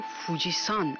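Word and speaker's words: فوجیسان (富士山): ژاپنی ها این کوه رو فوجیسان تلفظ میکنن. این سان پسوند احترام که به نام افراد اضافه میشه نیست، بلکه نحوه خانش کانجی کوهه.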